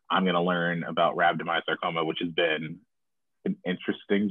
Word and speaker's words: rhabdomyosarcoma: Rhabdomyosarcoma.